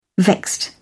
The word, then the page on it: vexed: British English